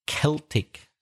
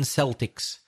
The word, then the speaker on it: Celtic: It's a funny word, that, C-E-L-T-I-C. Usually it's pronounced Celtic. It's a K sound. But sometimes it's pronounced S when it refers to sports teams for some reason. Glasgow Celtic Football Club.